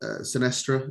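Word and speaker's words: Sinisterra: Sinestra, is that how you say it? – Yeah, it didn't play, but...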